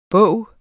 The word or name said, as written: bog